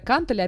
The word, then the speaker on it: кантели: Кстати говоря, кантели, опять же, ударение на первый слог, да, и самая популярная ошибка, собственно, у всех людей, все говорят «кижи».